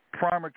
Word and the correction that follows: Pima: County, Arizona. I mispronounced it. PIMA, Pima County, Arizona.